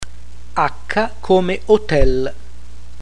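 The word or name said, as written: H Acca come Hotel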